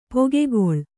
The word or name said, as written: ಪೊಗೆಗೊಳ್